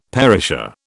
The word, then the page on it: perisher: British English